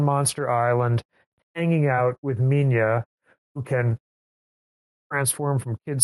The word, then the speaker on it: Minya: So it's Minya is the actual spelling, but it's often spelled, for decades, was spelled in English as Manila. So like Godzilla, but Manila. So, but Minya is a more correct pronunciation.